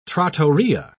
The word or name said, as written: trattoria